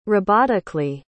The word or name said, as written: robotically